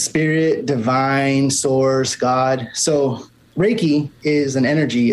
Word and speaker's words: Reiki: Because so the other thing that I had read on your website was Reiki Master. Am I saying it properly? – Reiki. – Sorry. Reiki, can you explain to us what that is? – Sure.